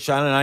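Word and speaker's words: Xanana: Well, he said that the name comes from, he pronounces Shanana, and he says it comes from the American.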